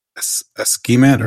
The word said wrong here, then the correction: Akismet: or Askimet. I don't know how to pronounce it, which is some... – Oh, Akismet. – Akismet, yeah.